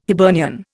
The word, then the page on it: Hibernian: Indian